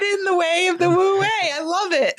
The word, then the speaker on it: Wu Wei: And then the way, which by the way, Wu Wei is spelled W-U-W-E-I. But it conveniently sounds like the word W-A-Y, way, in English, because it is about your way, W-A-Y.